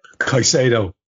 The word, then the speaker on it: Caicedo: Caicedo.